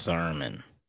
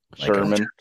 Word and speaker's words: Sermon: Did I say it right? Sermon. – Yes. Sermon. – Sermon. – Like a church.